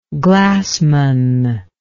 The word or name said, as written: glassman